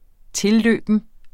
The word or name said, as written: tilløben